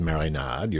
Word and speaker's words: marinade: So let's talk about a couple of marinade, or as Sasha says, marinade. You're doing the French pronunciation, I guess.